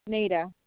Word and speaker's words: Neida: Nita Cazares. – Nita Cazares? – Nita. – Mm-hmm. Can you spell that for me? – Nita, N-E-I-D-A. – Okay. – C-A-Z-A-R-E-S.